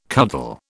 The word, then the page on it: cuddle: British English